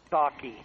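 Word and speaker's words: Psaki: Her name is pronounced Saki. Just the P is silent. Her name is just Saki.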